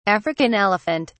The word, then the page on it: African elephant: British English